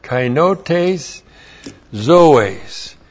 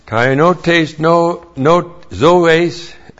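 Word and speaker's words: Kainotes zoes (καινότης ζωῆς): Kainote zoes. That's K-A-I-N-O-T, long E-S, Z, long O, long E-S. Kainotes zoes. Kainotes zoes.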